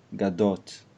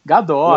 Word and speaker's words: Gadot: As vogais são mais curtas em hebraico, né? Então. Por quê? Você não acha? bom, pelo menos para mim, que eu tenho a pronúncia carioca, a gente está acostumado a falar as vogais. Gadot. – Muita, é, a gente abre muito, né? É. – É, é então... uma cantada, Gadot.